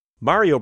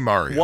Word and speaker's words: Mario: I say Mario because it's an Italian name. Too. Yeah, it's an Italian name, and Italy never had a vowel shift, unlike here in the Anglophonic world, where the long A suddenly took what was supposed to be a long E sound. And then the long E took what was supposed to be a long I sound.